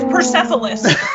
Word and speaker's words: Persepolis: It's Persepolis. – No, not Persepolis. You're not getting Persepolis. – Persepolis. – Yeah, hard P.